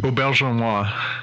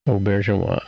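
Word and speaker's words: Auberjonois: Aubergenois. – Aubergenois.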